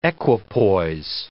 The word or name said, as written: equipoise